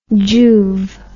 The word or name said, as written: juve